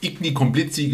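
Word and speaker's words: Igny-Comblizy: Also fuhr er durch die Lande und fuhr durch, ich kann das nicht aussprechen, Isli Komblizi. – Igni Komblizi, geschrieben wahrscheinlich Annie Komblizi.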